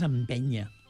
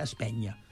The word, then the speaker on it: empenya: O sigui, en principi, en general, és empenya. Empenya, que alguns llocs se pronuncia espenya, no?